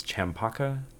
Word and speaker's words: champaca: Champaca? Is that how that's pronounced? – Sure.